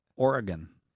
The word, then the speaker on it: Oregon: Kevin in Portland, Oregon. That's the Yeah. – correct pronunciation, you know, Oregon. – Oregon, yes. Not Oregon.